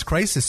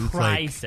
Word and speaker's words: Crysis: It's spelled C-R-Y. – Ooh, it's Crysis, so it's Crysis. – Like crying.